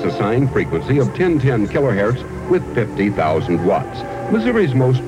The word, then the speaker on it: kilohertz: Oh, and by the way, we should comment that it's perfectly proper to pronounce that word kilohertz. Hertz like it hurts when you hurt yourself.